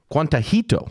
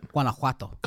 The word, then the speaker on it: Guanajuato: who he knew only as Guantajito. Did I say that right? – Guanajuato.